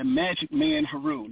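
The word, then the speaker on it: Majik Man Heru: And my pen and stage name is the Magic Man Haru.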